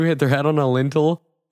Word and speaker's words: lintel: L-I-N. It's like Intel with an L in front of it. It literally, that's what it is. – A lintel.